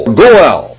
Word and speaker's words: Goel: The word G-O-E-L. Goel, Goel. Goel is the way I've heard it pronounced.